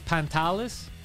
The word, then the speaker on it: Pantelis: Pantelis.